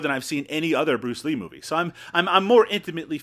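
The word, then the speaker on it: Bruce Li: And to be honest, I've also watched Fist of Fury 2 and 3, the Bruceploitation, I guess, versions, where it's Bruce, I guess it's still pronounced Bruce Lee, but it's Bruce L.I.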